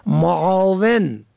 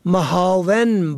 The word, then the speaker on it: معاون: یک کلمه دیگر که زیاد استعمال می شود معاون فرد دوم در یک پست که معنای کمک کننده دارد را محاون با معنای خار کننده نادرست تقریر می کنند که گویا فکر می کنند که ملح ادا کردند.